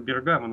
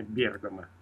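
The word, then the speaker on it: Bergamo: Да, у нас его иногда называют этот город Бергамо, но вообще-то правильно ставить ударение Бергамо.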